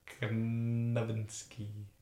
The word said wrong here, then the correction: Nowitzki: You added an extra N. – Novitsky. Oh, Novitsky, sorry. Novinsky. I thought you were going to say it's with the W, but you don't no. pronounce the W. Yeah, yeah. No, you do pronounce the W, but you pronounce an extra N. – No, but it should be a V sound because it's.